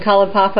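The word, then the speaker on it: Kalaupapa: So, I want to go back to these years that you spent on Kalapapa. Is that how you pronounce it? Yeah, on Kalapapa, and I'd like to ask you, what remains of this experience for you?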